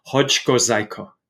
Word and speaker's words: Hoczko-Czajko: Yeah, You were perfect with Wojciech, and most people don't try my last name, but it's Hoczko-Czajko. It's a Polish name.